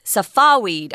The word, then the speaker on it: Safavid: It looks like it's Safavid, but if you look up the pronunciation, they actually say Safawid, and that was a dynasty or the people that were ruling Persia at that time.